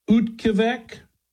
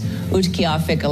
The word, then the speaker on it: Utqiagvik: I think they pronounce it in this clip. Utqavik. It's Utqiagvik.